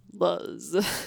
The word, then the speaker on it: Luz: No, you gotta say it like the computer said it. Luz.